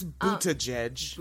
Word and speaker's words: Buttigieg: It's Buttigieg.